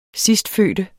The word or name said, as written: sidstfødte